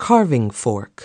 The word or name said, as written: carving fork